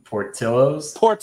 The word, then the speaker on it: Portillo's: Can you, as a Chicagoan, can you tell Andrew how to say Portillo's? – Portillo's.